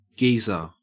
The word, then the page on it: geezer: Australian English